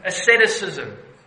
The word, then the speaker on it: asceticism: Asceticism. Have I pronounced it correctly? There we go. The good doctor said yes. Asceticism.